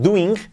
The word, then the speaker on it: doing: Pero que no es doing, es DOING, DOING, DOING. La i como una L e, letra clave en nuestro método y la g del final prácticamente no se pronuncia, DOING.